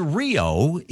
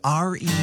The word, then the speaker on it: REO: It's not pronounced Rio. It's pronounced R-E-O.